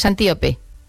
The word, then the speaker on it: Antíope: ahondas mucho en la relación de Teseo con Antílope, pero lo he dicho mal el nombre, es Antíope.